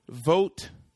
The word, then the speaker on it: Vogt: According to Collider, Jordan Vogt Roberts, I'm guessing the G is silent. – Yes.